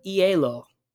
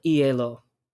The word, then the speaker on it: hielo: Number two, el hielo. El hielo. And this one has my favorite letter, H, at the beginning, H, which is silent in Spanish.